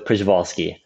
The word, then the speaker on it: Przewalski: Well, it really depends whether you want to pronounce it in Russian or Polish form. So you'll anger someone no matter how you say it. We can go with Przewalski.